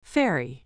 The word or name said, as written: fairy